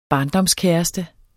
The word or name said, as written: barndomskæreste